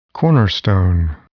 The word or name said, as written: cornerstone